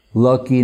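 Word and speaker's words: Lakhi: The goldsmiths, the gold shops in India, Bengal, very often they have a name like Lucky Narayan Babu, you know, and Lucky being, you know, a Bengalized of Lakshmi, because they drop some of the syllables, so it's L-A-K-H-I.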